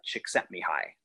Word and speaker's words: Csikszentmihalyi: There's a great book called Flow by author named, I don't know how to spell it, but his last name is pronounced Csikszentmihalyi. It's a famous book called Flow, F-L-O-W.